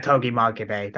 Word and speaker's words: Togi Makabe: We have the Antonio Inoki Memorial 6-man tag match between Yuji Nagata, Satoshi Kojima, Toji Maccabee, taking on Tatsumi Fujinami, Minoru Suzuki, and Tiger Mask. – Yeah, so Togi Makabe, that's how you say his name.